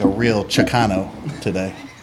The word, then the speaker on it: Chicano: Get all them Jacanos. – The Jacamos. – Jacamo. Jacamo. Jacamo. – Chicano. – Fucking spell it, please. One time. One of you three spell the word. – C-H-I-C-A-N-O. – One more time. – C-H-I. Chicano. Yes. C-H-I. C-A-N-O, Chicano.